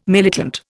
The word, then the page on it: militant: Indian